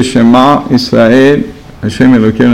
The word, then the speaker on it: Shema: and the ayin should be very qaliz, Baghdadi type, because if you pronounce it as an aleph, Shema means maybe or so what. So Shema means to accept and also to understand. Doesn't mean to hear. Shema Ayin has to be sounded different from Aleph.